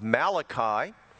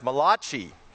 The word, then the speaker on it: Malachi: I want you to go to the last book of the Old Testament, the book of Malachi, or sometimes pronounced Malachi.